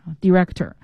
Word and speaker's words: director: 好， director 给大家拼一下 D-I-R-E-C-T-O-R director。另外一个要注意的就是这个单词它有两个发音，一个是 director，一个是 director。